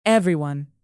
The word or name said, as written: everyone